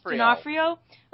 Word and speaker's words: D'Onofrio: D'Onofrio.